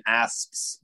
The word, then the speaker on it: asps: But with that said, to your angry serpent or fang's tooth or Egypt's asps or those types of things, and notice I pronounced the P in asps to make sure you heard it correctly.